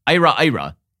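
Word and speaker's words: IIRIRA: The immigration law was called the Illegal Immigration Reform and Immigrant Responsibility Act, pronounced IRA-IRA.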